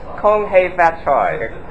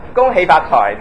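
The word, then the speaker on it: Kung Hei Fat Choi: Kong Hei Fat Choy. Kong Hei Fat Choy. That's the pure Cantonese, okay, pronunciation of that. It's from Cantonese.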